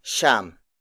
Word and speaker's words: siyam: Next is siyam.